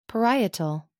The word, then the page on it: parietal: American English